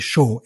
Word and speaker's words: Shaw: So it's S-T-E-P-H-E-N. And the surname is Shaw, S-H-A-W.